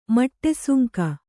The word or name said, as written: ಮಟ್ಟೆಸುಂಕ